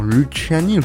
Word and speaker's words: Luciani: Et on va commencer avec Clara Luciani, Luciani. Enfin, vous prononcez comment? Luciani.